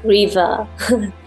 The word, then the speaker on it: Reewa: And even though it's with a W, but you pronounce it with a V, REEWA.